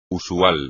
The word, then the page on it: usual: espagnol